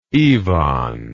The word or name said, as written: Ivan